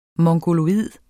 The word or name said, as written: mongoloid